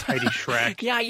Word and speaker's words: Heidi Schreck: And I butchered her name last week. It is Heidi Schreck.